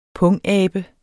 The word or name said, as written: pungabe